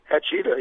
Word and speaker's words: Hachita: Hachita, yes, sir.